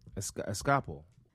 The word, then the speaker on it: Episcopal: How do you say it again, dude? This one gets me every fucking time. – Actually, you got it right. Episcopal.